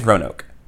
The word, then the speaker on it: Roanoke: So we got this house in, is it Roanoke or Roanoke? I don't know how to pronounce that city. I think it's Roanoke. – I believe that's correct.